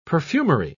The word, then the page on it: perfumery: British English